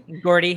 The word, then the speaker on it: Gourde: So I got Trocheck on the third line, Yanni Gourde. Or Gord, I'm sorry, I can't pronounce that guy's name. – Gordy. – Thank you.